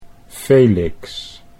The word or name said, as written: Felix